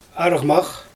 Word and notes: argmax: Xyzyl

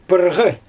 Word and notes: pyrGy: Xyzyl

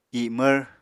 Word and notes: imvr: apatani

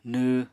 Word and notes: nww: Apatani

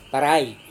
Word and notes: tarai: Serewen

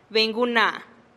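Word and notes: benih guna: Teotitlán del Valle Zapotec

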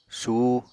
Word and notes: suh: Apatani

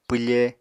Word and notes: pwfe: Apatani

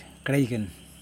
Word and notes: klaigin: Yokoim

